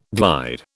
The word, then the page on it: glide: British English